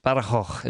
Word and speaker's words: Badajoz: I think near Badajoz, which is Badajoz, it's a difficult one to pronounce.